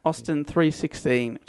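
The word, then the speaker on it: Austin 3:16: Austin 316, just like a biblical.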